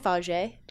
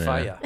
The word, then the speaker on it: Fage: What about Faya? Faya yogurt. – Is that how you say it? – Faya? Yeah, Faya. – Okay. I've always said Fage. Is that wrong? – I think it's Faya.